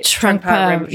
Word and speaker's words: Trungpa Rinpoche: Trungpa Rinpoche.